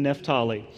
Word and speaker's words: Naphtali: The two regions that are mentioned are pronounced Zebulun and Naphtali.